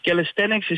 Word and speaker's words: calisthenics: Eigenlijk is het calisthenics. Dus de klemtoon leg je iets anders. Calisthenics is eigenlijk turnen, maar dan in een nieuw cool jasje.